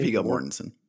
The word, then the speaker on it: Viggo Mortensen: Viggo Mortensen.